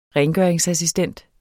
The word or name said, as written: rengøringsassistent